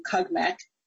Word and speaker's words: CUGMEC: So we use the acronym CUGMEC is how we pronounce it to refer to the program.